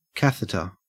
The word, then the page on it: catheter: Australian English